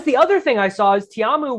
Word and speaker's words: Ta'amu: Ta'amu.